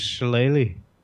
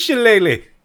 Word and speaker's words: shillelagh: His shillelagh. – His shillelagh. – His shillelagh.